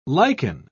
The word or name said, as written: liken